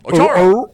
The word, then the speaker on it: Otoro: Otoro. – O-R-O-O. What are you, a harbor seal? – They're serving seal there too, man. I bet. Yeah, Greek seal. Otoro, I'm sorry. Do you know what Otoro is?